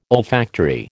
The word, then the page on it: olfactory: American English